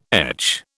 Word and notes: etch: British English

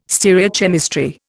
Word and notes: stereochemistry: Indian

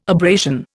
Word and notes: abrasion: Indian

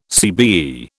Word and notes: CBE: British English

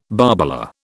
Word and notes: barbola: British English

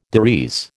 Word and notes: des res: American English

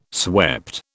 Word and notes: swept: British English

